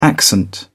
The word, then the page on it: accent: British English